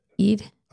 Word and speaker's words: Eid: Eid. That's why. Eid? Is that how you say it? – Eid.